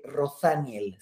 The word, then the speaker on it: Rothaniel: les recomiendo Rosaniel. Se escribe Rothaniel, Rosaniel, de Jerrod Carmichael, está en HBO.